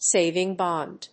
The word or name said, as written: saving bond